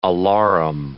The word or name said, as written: alarum